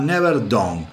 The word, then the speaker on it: Never Dawn: Never down sería, ¿no? Never down. El, el Tano Rapanelli debe decir, si está escuchando este hijo de puta, qué mal que pronuncia. Bueno, yo no pronuncio muy bien ya saben, soy medio disléxico y, no pronuncio muy bien. Se llama Never Dawn.